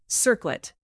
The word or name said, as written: circlet